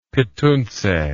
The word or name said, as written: petuntse